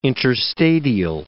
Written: interstadial

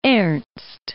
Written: Ernst

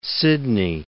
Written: Sidney

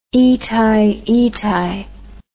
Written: itai itai

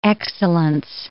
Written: excellence

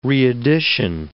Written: reedition

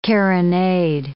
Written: carronade

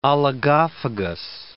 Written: oligophagous

